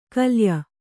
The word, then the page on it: ಕಲ್ಯ: kalya